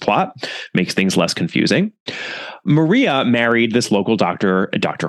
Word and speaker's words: Maria: And I'm pronouncing it Maria and not Mariah, because Tom Addison's wife was actually from Spain.